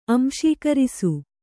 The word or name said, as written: ಅಂಶೀಕರಿಸು